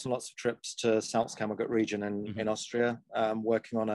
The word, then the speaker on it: Salzkammergut: For anybody who's not aware, wrote a piece on the Salzkammergut.